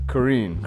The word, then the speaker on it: Kareen: Kareen. – Kareen? I said that right? – With an N.